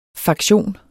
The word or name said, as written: faktion